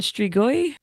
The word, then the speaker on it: Strigoi: And then how do you pronounce this one? Strigoi? – Yes, yes.